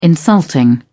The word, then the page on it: insulting: British English